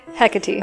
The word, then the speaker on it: Hecate: Is that how you say it? I always read it Hecate because I don't know things. Just like that's how it made sense. Is it Hecate? – Hecate. – Okay, good to know.